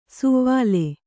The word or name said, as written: ಸುವ್ವಲೆ